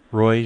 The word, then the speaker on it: Roy: Roy Gerbel. – Can you spell it for me? – Oh, sure. R-O-Y.